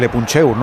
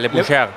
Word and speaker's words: Le Puncheur: No, lo has dicho bien, lo has dicho bien. – No, no se puede, pero se dirá en francés. – Se puede mejorar, Le Puncheur.